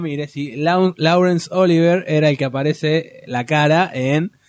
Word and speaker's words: Laurence: ¿No es Lawrence? ¿No se dice Lawrence? – Lawrence.